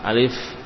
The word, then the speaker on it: alif: Alif, pengucapan alif tidak ada mad, ya. Dalam mengucapkan alif maka tidak ada mad. A-nya pendek, li juga pendek, alif, ya. Tidak dibaca alif tapi alif, ya.